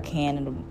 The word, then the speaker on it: cannabinoids: What are cannabinoids? That's how you say it.